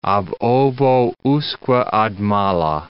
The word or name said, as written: ab ovo usque ad mala